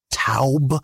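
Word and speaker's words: towb: And that word good in the Hebrew is taub. It's T-O-W-B, and Hebrew has those characters.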